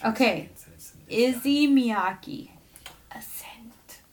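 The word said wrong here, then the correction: Issey: Is that how you pronounce it? Izzy? – A scent. Yeah, no, that's Izzy Miyake. – Issei. Issei.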